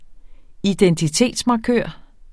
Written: identitetsmarkør

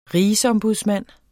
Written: RIGSOMBUDSMANDEN

